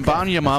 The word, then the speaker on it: Wembanyama: Wembanyama. – Victor right? – Wembanyama.